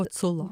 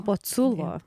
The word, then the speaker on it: Boculo: o kaip ištarti pavardę? – Boculo. – Boculo. Sergejus Boculo.